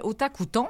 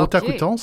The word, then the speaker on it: Otakuton: Alors, j'accueille présentement, on va accueillir oui, du festival Otakuton. Est-ce que je le dis comme il faut? – Oui, vous le dites bien comme il faut. Okay. Otakuton, c'est très facile à prononcer en français.